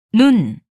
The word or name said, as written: 눈